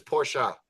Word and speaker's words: Porsche: The correct way to say Porsche is Porsche.